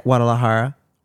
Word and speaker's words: Guadalajara: Guadalajara. – Guadalajara, the G silent? – Yes, the G is very silent.